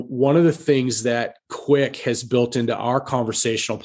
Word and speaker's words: Quiq: To our audience, Quick is spelled Q-U-I-Q, right? – Yes.